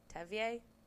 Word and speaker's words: Tevye: It's spelled T-E-V-Y-E. I don't... – Tevye? – I know, it makes me think like Kanye. – It's like Kanye, Tevye.